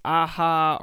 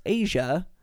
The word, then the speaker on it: Aja: I always thought this album was pronounced Aha or Aja. It's pronounced Asia, A J A.